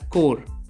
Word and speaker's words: Corps: Well, the spelling is C O R P S, yet it is pronounced as core.